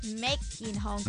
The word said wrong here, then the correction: made: 唔系 make 啊，made 系啦，五月嗰个发音咁样啦，但系有个 D 声系啊，made in，